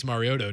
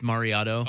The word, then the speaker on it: Mariota: Well, he worked with Marcus Mariota too. – Yeah, I think, but don't mispronounce it. You should, as a sports guy in this area, you should get that right. It's Mariota.